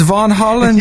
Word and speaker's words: Van Halen: I've never heard it said Van Halen before. That was an interesting pronunciation. – Did I say Van Halen? Shoot it in your vein, Van Halen. It's Von Holland, y'all.